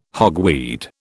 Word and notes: hogweed: British English

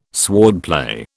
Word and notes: swordplay: British English

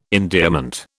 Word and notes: endearment: British English

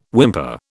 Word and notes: whimper: British English

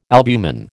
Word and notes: albumen: American English

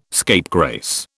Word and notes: scapegrace: British English

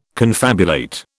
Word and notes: confabulate: British English

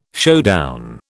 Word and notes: showdown: British English

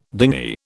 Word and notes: dingy: British English

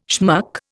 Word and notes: schmuck: Indian

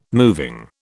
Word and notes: moving: British English

